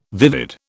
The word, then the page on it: vivid: British English